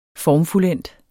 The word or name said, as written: formfuldendt